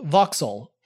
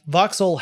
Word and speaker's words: Vauxhall: Sloan was head of GM when the company acquired an English company called Vauxhall in 1925. That's spelled V-A-U-X-H-A-L-L.